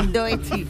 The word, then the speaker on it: Doechii: Y a ver, ¿esto cómo se pronuncia? – Doechii. – Muy bien.